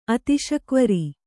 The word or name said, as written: ಅತಿಶಕ್ವರಿ